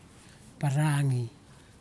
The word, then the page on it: paragi: kwamera